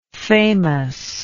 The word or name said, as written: famousness